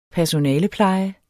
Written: personalepleje

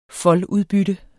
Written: foldudbytte

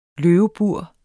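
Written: LØVEBURE

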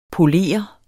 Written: poleret

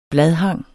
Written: bladhang

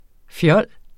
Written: fjols

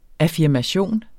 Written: affirmation

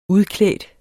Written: udklædt

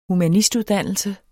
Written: humanistuddannelse

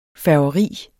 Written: færgeri

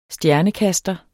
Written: stjernekaster

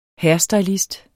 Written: hairstylist

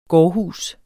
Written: gårdhus

